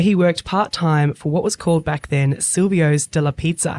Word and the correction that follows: Silvio's Dollar Pizza: Have I said that right, Don? – Silvio's Dollar Pizza, yes.